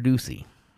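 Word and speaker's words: Doocy: The fact that Joe Biden admitted to Peter Ducey, that's how you say it, Peter Ducey, the little blonde-haired guy, that to Peter Ducey that the old Biden there said he didn't know how they got there.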